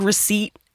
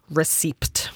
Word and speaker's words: receipt: Can you imagine looking at the word receipt and wondering what the fuck the P is doing there? Like, I'm just going to... I'm receipt. – Mm-hmm. – What? The P is silent? Then why the fuck is it there?